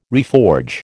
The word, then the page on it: reforge: American English